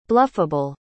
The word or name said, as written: bluffable